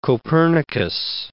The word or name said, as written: Copernicus